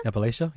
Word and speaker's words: Appalachia: Appalachia? – Appalachia, yes.